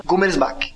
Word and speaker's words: Gummersbach: É, ele me explicou no Facebook como é que fala isso, cara. É quase como se escreve, deixa eu tentar, vamos lá, atenção, Gummersbach. Alguma coisa assim, enfim, tentei.